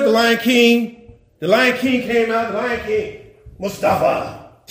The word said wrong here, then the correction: Mufasa: On July, did I say it right? Mufasa. Mufasa. The Lion King.